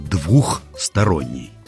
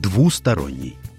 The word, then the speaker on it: двусторонний: Ну, есть еще одно слово – двухсторонний. – А что с ним? – С буквой «х» допустимо его произношение только в разговорной речи. Но правильно говорить и писать надо так – двусторонний.